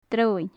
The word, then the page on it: droigheann: gaelic